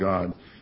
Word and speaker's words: Gad: We have Reuben, Simeon, Levi, Judah, Issachar, Zebulun, Joseph, Benjamin, Dan, Naphtali, Gad, which actually is pronounced God, and then Asher.